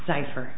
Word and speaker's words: Zyfer: Image Corporation and Zyfer or Zyfer? – Zyfer.